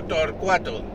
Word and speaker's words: Thor4: Me encuentro, me encuentro recomendando el podcast del amigo Torcuato. Eh, se escribe T-H-O-R 4, el número 4, que se llama Día a Día.